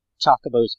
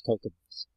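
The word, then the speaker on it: Chocobo: I still call Chocobos Cocobos.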